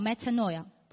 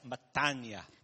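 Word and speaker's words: metania (μετάνοια): But my Greek teacher tells me, that's not how you pronounce it. – 那我的圣经老师，我的那个语言老师会告诉我说，这个不是你发音的正确方法。 – Metanoia is how the Bible student would pronounce it. – Metanoia 只是那些圣经学院的学生们发音的方法。 – But the Greek people pronounce it as metania.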